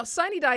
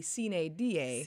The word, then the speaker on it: sine die: Now, sine die is how people in Georgia pronounce it. It comes from Latin, and if you were to pronounce it according to Latin guidance, it would be sine die.